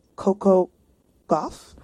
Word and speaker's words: Gauff: Yeah, we're gonna shout out Coco Gauff.